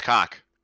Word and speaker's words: Koch: We got Nintendo, Xbox, Capcom, Konami, Ubisoft, Take-Two, Warner Bros., and Coke. I think it's pronounced Coke. K-O-C-H. Coke Media. – I believe it's Cock. – Cock Media? – I'm pretty sure it's Cock.